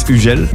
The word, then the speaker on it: Hugel: In French, it's Hugel. The English going like Hugo, you know, then I have the German going like man Hugel.